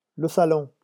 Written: le salon

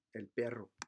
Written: el perro

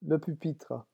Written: le pupitre

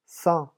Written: cent

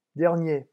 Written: dernier

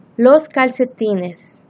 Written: los calcetines

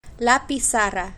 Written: la pizarra

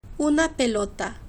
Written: una pelota

